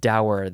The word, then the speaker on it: dour: Let's check the pronunciation: Uh, dur, dur or dour. I like dour.